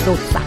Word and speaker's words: doccia: ma non dice proprio doccia, dice dozza, sai che vengono fuori, ma tu lo sai che vengono fuori dalla dozza.